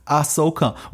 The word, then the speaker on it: Ahsoka: Also es ist Ahsoka.